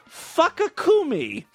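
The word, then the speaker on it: Fukkacumi: Fukakumi. That's spelled F-U-K-K-A, kind of like fuck, right? And then C-U-M-I, like come.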